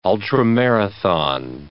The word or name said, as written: ultramarathon